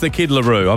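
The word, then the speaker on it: The Kid LAROI: The Kid Leroy next. Apparently I say that wrong. It's the Kid LaRue, I've been told, by an 11-year-old.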